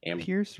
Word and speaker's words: Pierce: Pierce rhymes with fierce.